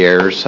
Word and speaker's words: Ayers: Chair, members of the committee, my name is Stacy Ayers. My last name is spelled A-Y-E-R-S, and I am the Child Protective Services Program Manager for Department of Human Services.